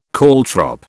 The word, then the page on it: caltrop: British English